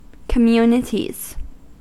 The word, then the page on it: communities: American English